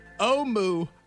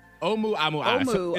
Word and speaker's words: Oumuamua: Pronounced Oumuamua. Oumuamua. It's Hawaiian. It's Hawaiian. – Oumuamua.